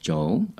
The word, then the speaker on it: Joel: So my Instagram is Joel De Carteret, pronounced Joel. Spelt Joel, but pronounced Joel. So J-O-E-L, De Carteret, D-E-C-A-R-T-E-R-E-T.